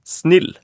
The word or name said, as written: snill